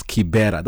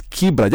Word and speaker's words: Kibra: So when you put those languages together, there is no word that you can pronounce as Kibera. That is a Muzungu thing for people who speak in Muzungu. Now, so it is Kibra.